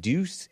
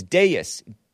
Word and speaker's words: Deus: And I pronounced it Deuce Intra. It's Deus, D-E-U-S, Deus Intra, God Within.